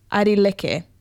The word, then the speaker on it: Airi Leke: His name is Aidi Kele. Oh, no, sorry. I butchered that. Aidi Leke, I think is how you say that. A-I-R-I-L-E-K-E is how you spell it.